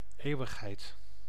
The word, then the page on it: éternité: [e.tɛʁ.ni.te]; France